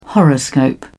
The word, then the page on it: horoscope: British English